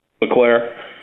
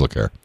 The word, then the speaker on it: Leclerc: Ferrari, the guy that had the fuel issue, Leclerc, or? – Claire. – How do you say it? – Leclerc. – Oh, it is Leclerc.